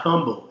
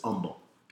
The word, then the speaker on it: Humble: This is in Humble, Texas, not Humble. It's spelled with an H, but it's Humble.